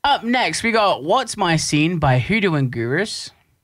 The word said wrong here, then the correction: Hoodoo Gurus: I beg your pardon? – Did I say that right? – No. – Hoodoo Gurus? – Yeah, no, Hoodoo Gurus. There, you got it.